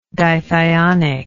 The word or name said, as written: dithionic